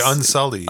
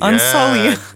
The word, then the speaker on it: Unsullied: Oh my God, I'm totally going to butcher this. – What are they? The Unseelie? – The Unsullied. – Unsullied. Yeah. So the Unsullied.